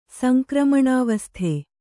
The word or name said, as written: ಸಂಕ್ರಮಣಾವಸ್ಥೆ